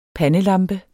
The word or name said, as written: pandelampe